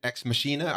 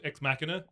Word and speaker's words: Ex Machina: Some people said Ex Machina. I say Ex Machina. What? How do you say it, Joe? It's your choice. – It was my choice. I vote Ex Machina.